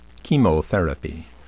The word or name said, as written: chemotherapy